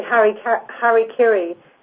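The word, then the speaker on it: hara-kiri: Is that how you say it? – Well, I think we say harikiri.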